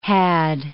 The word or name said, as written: had